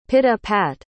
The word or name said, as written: pit-a-pat